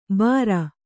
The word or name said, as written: ಬಾರ